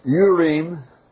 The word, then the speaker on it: Urim: This plural at the end, a little sign with an M, that is pronounced Im. And Urim, not Umin, Urim is the proper word.